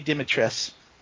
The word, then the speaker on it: Dimitrescu: Apparently it's Lady Dimitres. – Okay. Because her name is spelled very Romanian. Dimitrescu. – Yeah. Yeah, I've heard it said different ways, but in the game they actually say it Dimitres, so yeah.